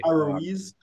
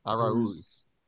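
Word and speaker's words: Araúz: Aruiz. – A-R-A-U-Z? R-U-I? – Aruiz. – A-R-A-U-Z, but the U has like an accent mark. – Aruiz. Not to be confused with Arise.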